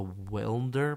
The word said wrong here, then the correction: Wendler: program, the 531 method. – Wendler? It's Wendler. Yeah, there you go.